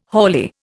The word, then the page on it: haulier: Indian